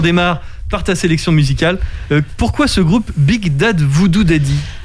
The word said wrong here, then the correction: Big Bad Voodoo Daddy: Big Bad Voodoo Daddy.